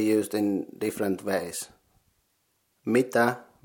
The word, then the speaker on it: mitä: Combining them you get mi tä. Mitä.